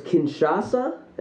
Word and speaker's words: Kinshasa: That was Kinshasa. Am I? Were we pronouncing that right? – That's correct.